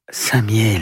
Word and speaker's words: Saint-Mihiel: Pour remercier Saint-Michel, la ville adopte son nom, mais mal prononcé. – Saint-Mihiel.